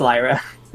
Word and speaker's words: Lyra: I keep going between Lyra and Lyra. – It's Lyra. – All right. – Like a liar.